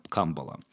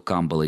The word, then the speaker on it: камбала: Кабала и камбала. Ну, по поводу камбала и камбалы мы как-то уже говорили, что и то, и другое ударение в русском языке допустимо.